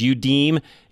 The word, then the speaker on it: Youdim: And it's Udim. And I am actually saying it the right way. It's spelled Y-O-U-D-I-M.